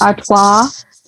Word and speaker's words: Artois: Artois.